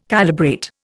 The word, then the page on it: calibrate: Indian